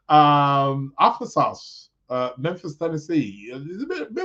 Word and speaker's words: Arkansas: Arkansas. Yes, Arkansas.